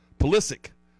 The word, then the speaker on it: Pulisic: Pulisic. Did I say it right? Christian Pulisic? – That's very close.